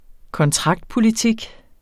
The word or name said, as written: kontraktpolitik